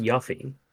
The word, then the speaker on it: Yuffie: You know, she was, it's actually pronounced Yuffie.